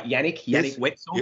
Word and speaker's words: Yannick Wetzel: The biggest thing for me, Yannick Wetzel. Am I saying that right? Yannick Wetzel. Yep.